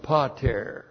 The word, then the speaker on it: pater: The dative plural of pater. P-A-T long E-R. Pater.